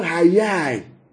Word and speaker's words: hayah: The word let there be is the Hebrew word hayai. Yeah? The word let there be is the Hebrew word hayai. H-A-Y-A-H.